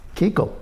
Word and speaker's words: Kuchel: Think that Tom Kuchel, is that how you pronounce it? Kuchel? Tom Kuchel? Kiko. – Kiko? K-U-C-H-E-L? – K-U-C-H-E-L. – Kiko. He's pronounced Kiko? – Yeah.